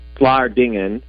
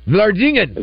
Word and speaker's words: Vlaardingen: Well, I would call it Vlaardingen. – Vlaardingen.